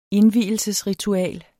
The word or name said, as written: indvielsesritual